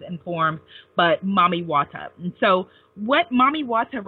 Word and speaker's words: Mami Wata: Yeah, and then Mami Wata, you know, which is how she's pronounced throughout the diaspora, but it's like Mommy Water.